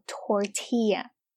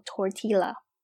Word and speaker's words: tortilla: 那这个玉米饼呢，我们叫做 tortilla。那注意呢，因为这是西班牙语，所以呢我们在发音的时候不能叫做 tortilla。那这个玉米饼啊，就是我们平时比如说像在肯德基里面点的墨西哥卷啊，用的那个饼就叫做玉米饼。那再说回来，那这个玉米片呢，就叫做 tortilla chips。